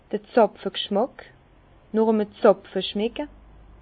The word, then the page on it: d'r Zàpfeg'schmack, nooch e'm Zàpfe schmecke: Bas-Rhin; Herrlisheim